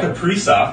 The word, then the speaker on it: Kaprizov: No, but so now we got obviously Kaprasov. – Yep. – Kaprizov, right? – Kaprizov. – That's right, you assholes.